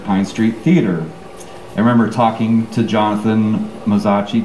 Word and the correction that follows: Mozaki: Did I say that right? Mozaki? Yeah, no. Mozaki. – Mozaki.